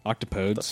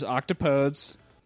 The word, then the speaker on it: octopodes: Well, there's controversy on how to pronounce it. Some people say octopode. Octopodes. – The fuck? Get out of my house. – But the proper way to say it is... there's octopodes, and then there's octopodes. That's the proper way to say it is octopodes.